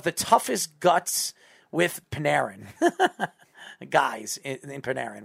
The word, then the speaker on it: Panarin: Pronounce his name right. It's Panarin. – Say it again. – Panarin. – Panarin. – Yes, Panarin.